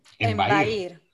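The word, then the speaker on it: embaír: Embair. Embair con B de Barcelona. – Sí, señora, y acento en la I.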